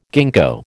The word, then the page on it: ginkgo: American English